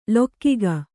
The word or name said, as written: ಲೊಕ್ಕಿಗ